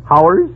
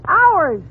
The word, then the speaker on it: hours: Now, how do you pronounce H-O-R-S-E? – H-O-R? Oh, that's easy. That's horse. – Fine. Now, how do you pronounce H-O-U-R-S? – H-O-U-R-S? Howers? – You can pronounce it ours.